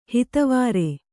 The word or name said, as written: ಹಿತವಾರೆ